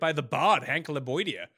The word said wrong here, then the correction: Lebioda: Yeah, Lebioda.